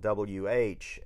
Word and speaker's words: Whakaari: So it's about a 90-minute documentary called Volcano: Rescue from Whakaari, and Whakaari is spelled Whakaari, but the Maori people pronounce it Whakaari. – So while a lot of English speakers call it Whakaari, and the island itself is referred to as White Island because that's what the English called it.